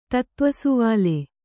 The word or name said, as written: ತತ್ತ್ವಸುವಾಲೆ